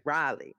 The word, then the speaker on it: Raleigh: And I was on the plane with this nice older gentleman, and we started talking, and he was like, "You remember to say the name right. It's Raleigh. You got to say Raleigh."